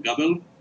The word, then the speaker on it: Gabel: My name is Tomer Gabel.